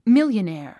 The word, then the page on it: millionaire: mìljənéə r; ミリオネア